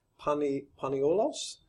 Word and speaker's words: paniolo: The Hawaiian word paniola may even be based on Hawaiian's pronunciation of paniolos. Is that correct? – Paniolo. – Oh. Paniolo.